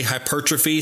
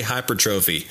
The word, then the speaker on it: hypertrophy: Some people say hypertrophy. Some people say hypertrophy. Whatever way it's pronounced.